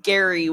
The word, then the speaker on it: Gerry: His name's spelled G-E-R-R-Y. So it's like, I don't know if it's Jerry, Gary, or Gary. I'm gonna go with it's pronounced Gary, which is messed up.